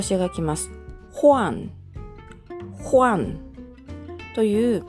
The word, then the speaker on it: 换: 発音記号は HUAN 第 4 声上から下に急降下する音になります。ホアン、ホアン。